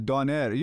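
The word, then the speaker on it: Döner: We're probably saying it wrong, too. Turkish people are like Donair. You're actually closer to the correct version. – You got to roll the R.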